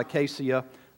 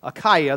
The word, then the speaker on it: Achaia: Achaia is the actual translation, not Acacia. Achaia, that's why I slowed down.